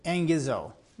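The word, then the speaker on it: engizo (ἐγγίζω): Okay, so in that word for at hand, or in some translations it'll say near, is engizo. Okay, I know that doesn't sound, there's no N in the word, but that's how you pronounce that Greek word.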